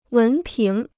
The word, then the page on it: 文凭: wén píng